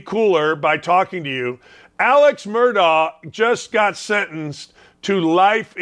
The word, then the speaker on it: Murdaugh: Yeah, I watched the Netflix documentary, and the whole time I was just confused because they were saying Alex Murdaugh, but it's spelled Alex Murdaugh.